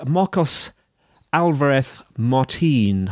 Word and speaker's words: Marcos Alvarez Martin: Have I got your name right, Marcos? Because I know it has to be pronounced in a special way, doesn't it? – Yes, that's right. You got it right.